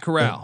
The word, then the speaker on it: Corral: Matt Corral. – Corral. Yeah. – Okay, Corral. – Okay, Corral.